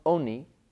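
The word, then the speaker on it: oni (עני): Interestingly, it's pronounced oni, it's pronounced as if there was a vav, but there is no vav. In the way the Torah is written, it's ayin nun yud, which sounds like ani, meaning a poor person.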